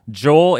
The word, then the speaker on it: Joel: So there was, I think, with there was a story with Joel Eriksson Ek, where I think people were doing a soft J, but in the pronunciation guide here that we have, it says J O H L all uppercase. Joel Eriksson Ek.